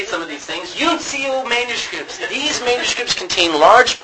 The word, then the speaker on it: uncial: The third group of manuscripts are called uncle. No. Unical, or U-N-I-C-I. Is it I-A-L? Yeah. U-N. I'm not. U-N-C-I-A-L. Uncial. That's how you pronounce it.